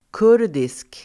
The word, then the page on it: kurdisk: [k'ur:disk]